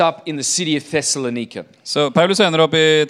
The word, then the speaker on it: Thessalonica: Did I pronounce it correctly? You pretty, probably know. Pretty close. – Yeah. Thessalonica.